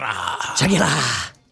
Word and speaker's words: Shakira: Shakira. – Dan, I hope you heard that out in the newsroom. That's how you properly pronounce it.